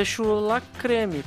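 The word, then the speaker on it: chou la crème: é, chu, mas o nome em francês é chou la creme, cara.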